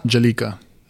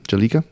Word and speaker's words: Bjelica: The Kings deal Namja Bjelica. – Jalika. – Jalika. Okay. Silent B.